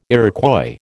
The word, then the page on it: Iroquois: American English